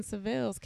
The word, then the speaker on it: Sivills: And um, my personal page is Kristen Sivills K R I S T E N S I V I L L S.